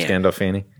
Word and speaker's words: Gandolfini: Gandolfini? – James Gandolfini.